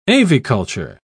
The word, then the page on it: aviculture: British English